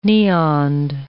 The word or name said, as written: neoned